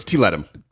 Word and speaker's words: Tiletum: How to pronounce? – Tiletum. Tiletum. But we don't, in English, tum is awkward. So it's Tiletum.